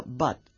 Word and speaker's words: but: Por ejemplo, but, que se escribe con U, pero se pronuncia con A.